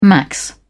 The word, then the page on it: max: British English